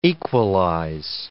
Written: equalize

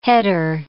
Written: header

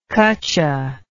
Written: kutcha

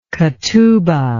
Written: ketubah

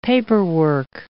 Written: paperwork

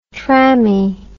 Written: trammie

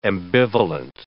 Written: ambivalent

